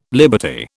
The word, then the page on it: liberty: British English